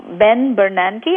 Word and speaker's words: Ben Bernanke: Ben Bernanke, am I pronouncing the name right? – Yes, yes.